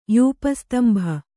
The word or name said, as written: ಯೂಪಸ್ತಂಭ